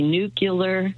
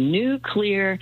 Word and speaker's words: nuclear: And then the third one that people mispronounce all the time is when they say nuclear, not correct. So if you, it's cloudy today, and maybe, but tomorrow it's going to be a new clear day. Just say nuclear, and you will pronounce nuclear correctly.